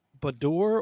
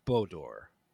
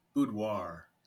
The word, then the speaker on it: boudoir: And I don't know if I say it right, is when it comes to photography, is it boudoir or Bodor? – Boudoir. – It's boudoir. French.